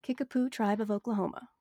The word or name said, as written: Kickapoo